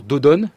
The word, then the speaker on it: Dodon: En Moldavie, Igor Dodon, je ne sais pas si c'est exactement comme ça qu'on prononce, oui, me dit Florent Parmentier, est élu.